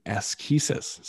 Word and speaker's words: asceticism: It is from asceticism. So you were close. You had the first syllable right. But asceticism, which means practice and exercise.